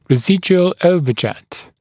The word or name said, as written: residual overjet